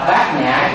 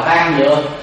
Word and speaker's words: Bát Nhã: Chữ đó chúng ta phải đọc Bát Nhã chứ không có đọc là Ban Nhược.